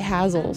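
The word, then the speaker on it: Hasil: And then I was gonna say Hazel, but there's some really good pronunciation on the record itself. It's actually Hazel, so this whole time I've been saying Hazel, but it is Hazel Adkins.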